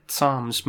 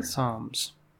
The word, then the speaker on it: Psalms: Am I saying that right? I've said it Psalms my whole life. – Yeah, that's right. – Psalms. There's a lot of extra letters in that word.